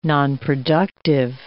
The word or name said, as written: nonproductive